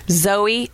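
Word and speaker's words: Zoje: Zoe. I think it's Zoe. It's Z-O-J-E. I think it's Zoe, though. Zoe Stage. – And the J is silent? – I think.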